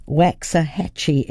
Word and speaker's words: Waxahachie: And Waxahachie is spelt W-A-X-A, as in Waxa, Waxa. We'd say Waxa, but it's actually Waxa. Waxahachie, as in Waxa, hat, and chi for cheese.